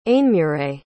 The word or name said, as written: ainmuire